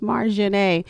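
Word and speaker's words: Marjané: But yeah, so it is Marjane, M-A-R-J-A-N-E, accent over the E, please. – Right. – It's pronounced Marjane.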